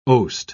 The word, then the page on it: oast: British English